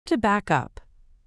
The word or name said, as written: to back up